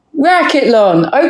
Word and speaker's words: racquetlon: Rackleton. – Racquetlon. Okay. – Oh, is that how you say it? – Racquetlon. – Racquetlon. – Yes, it's okay. – Like decathlon, triathlon, racquetlon with rackets.